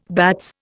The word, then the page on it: bats: Indian